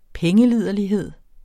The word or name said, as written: pengeliderlighed